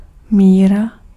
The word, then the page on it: measure: /ˈmɛʒə/; /ˈmɛʒ.əɹ/